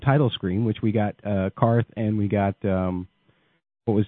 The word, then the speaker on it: Qarth: I mean, I know I'm throwing Matt's tomatoes all over this episode, but when she's speaking to the scout in Dothraki, she pronounces it correctly. She calls it Qarth.